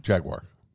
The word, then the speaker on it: Jaguar: Did I say it right, by the way? No. Jaguar.